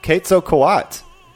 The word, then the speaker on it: Quetzalcoatl: Quetzalcoatl. – Oh. – Yeah, we were all way off. – I was pronouncing all the letters, and I didn't need to do that.